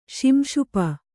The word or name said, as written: ಶಿಂಶುಪ